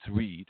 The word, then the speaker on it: Ried: You just type in my full name, John Paul Ried, R-I-E-D. Yes, it's good German spelling though. No, it's not Ride. It's Ried, R-I-E-D.